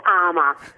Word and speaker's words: ARMA: Is it ARMA or ARMA? – It's ARMA. – So we pronounce it that way, do we? – We do, yes, we do.